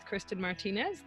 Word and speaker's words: Kristin Martinez: Well, today I'm here with Kristen Martinez. Did I say that correctly? – Yes, you did.